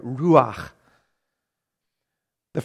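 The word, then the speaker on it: ruach. The (רוח): verse 2 is the Hebrew word ruach. And you have to get that good little guttural sound in there if you want to say it right. Ruach. The phrase in Hebrew is ruach Elohim.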